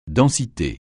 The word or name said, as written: densité